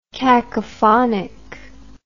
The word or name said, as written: cacophonic